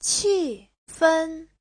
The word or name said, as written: qi4fen1